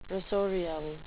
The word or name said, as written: rasorial